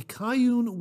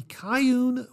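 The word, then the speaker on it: Cayuen: And he writes here, today I got Voodoo King in from the Kyun Workshop. Now that's spelled C-A-Y-U-E-N. I believe I'm pronouncing that correctly. The Kyun Workshop.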